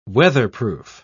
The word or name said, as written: weatherproof